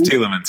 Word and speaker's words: Tielemans: You got it right. It's Tielemans. Yep.